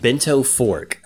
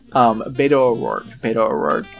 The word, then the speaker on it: Beto O'Rourke: Bento Fork. I'm purposefully mispronouncing it now. – Beto O'Rourke.